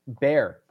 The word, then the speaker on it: Bjørn: So I incorrectly pronounced Bjorndalen. That B-J-O-R-N is pronounced bear, apparently.